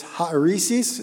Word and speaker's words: hairesis: But the word is spelled H-A-I-R-E-S-I-S. It's haeresis is how it's pronounced.